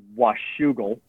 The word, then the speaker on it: Washougal: I was born and raised in a small town called Washougal, That's spelled W-A-S-H-O-U-G-A-L.